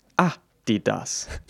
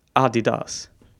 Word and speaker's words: Adidas: If you're American, you're probably pronouncing the sneaker brand, brand Adidas as A D that's completely wrong. – It's pronounced Adi Adidas. what? The emphasis should be in the first syllable, and the last syllable should be stretched out. Adidas.